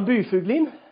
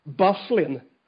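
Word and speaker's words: Byfuglien: Du har ju ett perfekt uttal på efternamnet. – Jag ska ha det norska. – Ja. – Ja, Byfuglin. – Byfuglin. Och i NHL heter han? – Bufflin. – Bufflin, ja.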